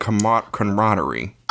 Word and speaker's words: camaraderie: But I feel like this podcast has become more about friendship and camaraderie. – Oh, my God. – Did I say that word right? – Camaraderie? Yeah. You said it right.